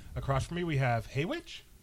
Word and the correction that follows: Haymitch: Hey, I believe it's called pronounced Haymitch. – Dang it. Oh, that's an M, not a W. It's across from me, we have Haymitch.